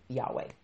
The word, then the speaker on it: Yahweh: and it's pronounced basically Yahweh.